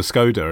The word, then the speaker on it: Skoda: It reminds me, I did a voiceover once for Skoda, and apparently that's Skoda.